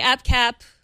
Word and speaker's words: AppCap: Okay, AppCap, because app rhymes with cap, and we are talking about our favorite apps.